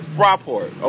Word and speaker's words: Fraport: Now, let me see if I pronounce this correctly, because I know you got your German together. Let me work on mine. The Fraport Skyliners? – Fraport. – Okay, Fraport Skyliners.